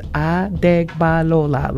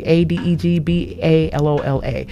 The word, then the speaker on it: Adegbalola: And before that, I played Let Go, Let God by Gay Adegbalola, which is Adegbalola, like Adegbalola.